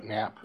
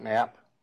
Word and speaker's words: Knapp: Brett Knapp. – Morning. If you could state your name for the record. – Brett Knapp. – Right. Can you spell that, please? – K-N-A-P-P. – K-M-A-P-P? – November. – K-N-A-P-P, did I get that right? – Yes.